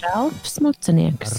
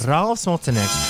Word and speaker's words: Ralfs Mutanieks: Es saklausīju pareizi, Ralfs Mutanieks, to ar kaut kā aiz, aizrunāju garām. Bišķi. – Ralfs Mutanieks. – Ralfs Mutanieks.